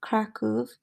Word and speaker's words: Kraków: Or Kraków. I need to learn how to pronounce things how they should be and not like the American English way. So Kraków. So I'm familiar here with the transport system.